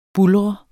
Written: buldrende

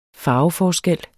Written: farveforskel

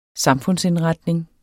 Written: samfundsindretning

